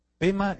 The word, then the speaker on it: Pema: Pema es la pronunciación tibetana, pero el nombre en realidad es Padme. Padme en hindú, Pema en tibetano.